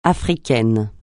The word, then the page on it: Africaine: francais